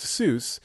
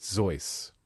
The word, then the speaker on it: Seuss: Is regularly pronounced Seuss, an anglicized pronunciation inconsistent with his German surname. He himself noted that it rhymed with voice, and his contributor, Alexander Liang...